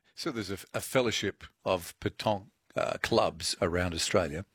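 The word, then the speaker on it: pétanque: And what's well, talk to us about the club, the Wollongong Petonk Club. You actually don't pronounce the K, do you? – The true no, French no. Petonk. Yeah, because it's Q U E, spelled with Q U E.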